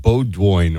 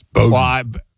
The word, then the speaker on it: Bowdoin: I think I should just get credit for knowing that it's not Bowdoin or whatever. It's Bowdoin.